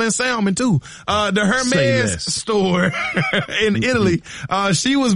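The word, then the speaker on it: Hermès: Savannah James was on a podcast the other day, and she was speaking about her experience at a Hermes store in Hermes. – Italy. Is that how you see? I always hit the H. – The H is silent.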